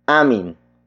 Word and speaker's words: amin: And that is amin. Amin. Amin. Amin is spelled as A-M-I-N.